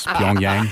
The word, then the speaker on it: Pyongyang: It's Pyongyang.